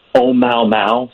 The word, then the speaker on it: Oumuamua: Yes, it is what is exciting about it, it's pronounced Oh Mau Mau, sort of like that song Day Bow Bow.